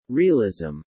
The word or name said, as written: realism